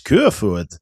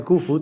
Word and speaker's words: Koevoet: Ik was daarbij en ik was toen een van de weinige mensen die zeiden Jens Koevoet. Spreek je dat in het Deens uit namelijk. – Ja, zo vaak spreek ik hem ook weer niet, Jens. Dus die naam, ik zeg gewoon lekker Koevoet. Lekker op zijn Nederlands.